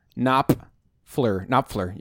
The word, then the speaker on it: Knopfler: It's got a P and an F. Knopfler. You can't say it fast.